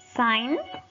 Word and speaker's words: sign: La primera es silent G, la G silenciosa. No vamos a pronunciar la G en estas palabras. Normalmente cuando va antes de una N, cuando va antes de una N, no la vamos a pronunciar. Sign, firmar. Campaign, campaña. Y champagne, champaña.